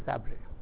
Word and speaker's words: Sabre: It's called Sabre. I think it's S-A-B-R-E. I'm just pronouncing it Sabre.